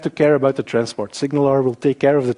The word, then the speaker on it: SignalR: Just to make sure you know how to pronounce it, it's SignalR, like the pirates. So SignalR.